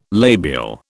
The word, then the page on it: labial: British English